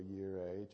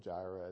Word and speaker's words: Jireh: I mean, even the Old Testament, Jehovah Yireh, Jehovah Jireh, as we mispronounce it.